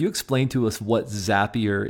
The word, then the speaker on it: Zapier: Wade is the CEO and co-founder of a company called Zapier, rhymes with happier, I discovered in the midst of doing the research.